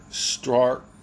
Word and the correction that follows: Strzok: I totally butchered that guy's last name. Peter Stork, S-T-R-Z-O-K, Stork.